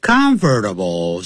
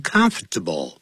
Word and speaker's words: comfortable: Comfortable 是四个音节，可是老外常常不会讲 comfortable。他实际会念成 comfortable， comfortable 变三个音节，必须要很舒适才行。